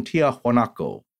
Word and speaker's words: Tiahuanaco: And this area is one of South America's most impressive cultural archaeological sites, and it's where they built their capital there, Tiwanaku. Although you'll hear Professor Barnhart pronounce it in the Spanish variation, Tiahuanaco.